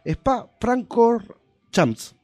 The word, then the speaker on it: Spa Francorchamps: Spa Francorchamps. ¿Lo dije bien? – Sí.